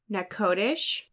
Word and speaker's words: Natchitoches: It's Natchitoches, Louisiana, but it's spelled Natchitoches.